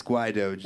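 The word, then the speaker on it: Guaidó: Guaido, is it Guaido? On the Wikipedia, right at the person's name, it'll have their pronunciation. Like, it's literally the first thing. – Yeah, it's Guaido.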